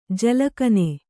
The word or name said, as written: ಜಲಕನೆ